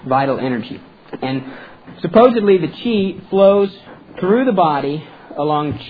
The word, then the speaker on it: qi: That's how you spell qi. Believe it or not, sometimes it's spelled Q-I. Okay? I don't know why. But qi, like you've heard maybe Tai Chi. Okay, that's an example of that.